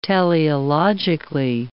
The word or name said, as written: teleologically